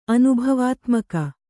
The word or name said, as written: ಅನುಭವಾತ್ಮಕ